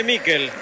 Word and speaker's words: Mikel: ¿Cómo le decimos? ¿Michael o Mikel? Nicolás Álvarez, usted que es especialista en nombre. Se escribe Mikel. ¿Cómo le digo? – Decirle Mikel. – Entonces le digo a Mikel, usted me enseña y yo aprendo.